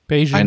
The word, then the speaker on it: Bayesian: Ich habe, ähm, ich weiß nicht, wie spricht man die aus? Bayesian Curls. – Bayesian, einarmige, Bayesian Curls, ja.